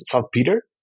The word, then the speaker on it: saltpeter: The common input was, what's the pronunciation in English as saltpeter? Is that correct? – Yep.